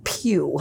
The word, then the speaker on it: Pugh: Florence Pugh.